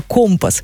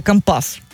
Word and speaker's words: компас: Это слово «компас». Хотя говорят, что у моряков свое профессиональное ударение «компас».